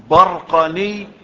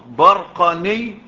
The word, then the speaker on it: البرقاني: البرقاني وهي تنطق برقاني وبرقاني يعني تنطق بفتح الباء هكذا قال السبكي،